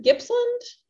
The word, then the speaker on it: Gippsland: I'm going to say it wrong, but New Gippsland? – Gippsland. – New Gippsland. Thank you for finding it for me.